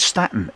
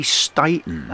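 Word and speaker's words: Staton: I must stop saying Staten, it's Candy Staten, that's how you pronounce her surname.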